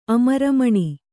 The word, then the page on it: ಅಮರಮುನಿ: kannada